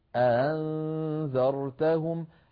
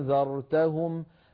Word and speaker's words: أأنذرتهم: أنذرتهم، هكذا نطقها الهمزة المسهلة، نطقها بين الهمزة المحققة وبين الحرف المد المجانس لحركتها، هنا مفتوحة أنذرتهم،